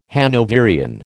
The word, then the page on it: Hanoverian: American English